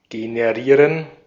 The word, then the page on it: generate: /ˈdʒɛn.əɹ.eɪt/; /ˈdʒɛn.ə.ɹeɪt/